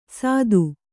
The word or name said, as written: ಸತ್ತು